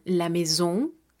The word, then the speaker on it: maison: Cuando encontremos las vocales A y juntas, estas las estaremos pronunciando como si fueran una E normal en español. Algunos ejemplos serían la maison, que quiere decir la casa.